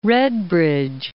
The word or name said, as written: Redbridge